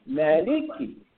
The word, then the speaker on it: Maliki: It is a long A, like ah, and this is L, and this is K, Maliki.